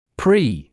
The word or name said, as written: pre-